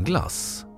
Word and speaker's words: Glace: Stavningen glass med G-L-A-C-E är ett mer direkt inlån från franskan än den försvenskade formen glass, alltså G-L-A-S-S. Men båda uttalas på samma sätt.